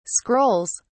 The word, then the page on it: scrolls: British English